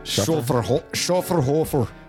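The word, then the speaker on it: Schöfferhofer: Well, now I'm really disappointed because I saw the alcohol by volume, but this is a Schafferhofer. Is that how you say it? – Schafferhofer.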